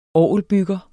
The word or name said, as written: orgelbygger